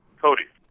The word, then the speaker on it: Cote: Yeah, aides to this guy, David Cote, or Cote, is that how you pronounce it? – Cody.